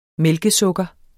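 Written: MÆLKESUKKER

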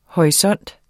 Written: HORISONTERNE

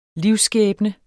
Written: livsskæbne

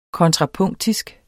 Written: kontrapunktisk

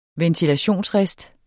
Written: ventilationsrist

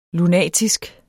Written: lunatisk

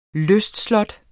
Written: lystslot

